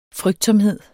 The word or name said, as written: frygtsomhed